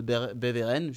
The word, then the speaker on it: Beveren: Beveren, je sais pas si je le dis bien, oui.